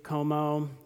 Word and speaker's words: Comeau: Dave Como, my last name is C-O-M-E-A-U, yet there's an O sound at the end, and it really doesn't make any sense. So if you want to just remember it as C-O-M-O, that might be easier for you.